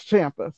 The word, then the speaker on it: Ciampa: I've heard Tommaso Ciampa's name pronounced Ciampa. I've heard it pronounced Champa. I've heard it pronounced Champa. But he says Champa, so I say Ciampa.